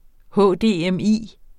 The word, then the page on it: HDMI: ˈhɔˀˈdeˀˈεmˈiˀ; hɔdeεmˈiˀ